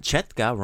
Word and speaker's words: četka: But četka rhymes with the Croatian word for ant, tetka.